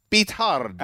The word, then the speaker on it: Beathard: Is his name CJ Beathard? – Well, he's Chris. That's just a guess. Beathard. You didn't know that's how you actually pronounce it? – I didn't know that.